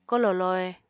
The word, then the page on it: kolò lohe: Apatani